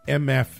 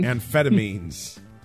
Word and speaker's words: amphetamines: So he was due in court for a pretrial hearing on charges of, all right, here we go, trafficking in MF. Why can't I pronounce that tonight? – Amphetamine? – Amphetamines.